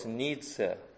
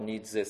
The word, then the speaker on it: Nietzsche: His name was Nietzsche. My pronunciation is done. I know how the Germans pronounce, I pronounce it, and probably this is right.